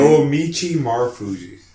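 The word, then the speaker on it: Naomichi Marufuji: Is Mike Elgin and I don't know how to pronounce his name. Noamichi Marufuji. – Okay, yes.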